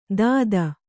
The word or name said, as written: ದಾದ